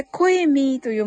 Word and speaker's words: COEMEE: COEMEE ですね。これ、コエミーと読むんですが、はい。